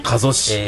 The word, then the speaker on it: 加須: 加須市。